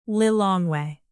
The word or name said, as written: lilongwe